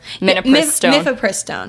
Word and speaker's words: Mifepristone: No, Mifepristone. Am I saying it right? – Yeah. – Mifepristone. – Whatever. I don't know. – You guys know what we're talking about. – Mifepristone.